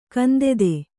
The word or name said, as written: ಗಂಧಿತ